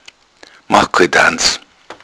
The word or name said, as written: danz